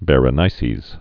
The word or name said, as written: Berenice's Hair